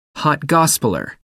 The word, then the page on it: hot gospeller: American English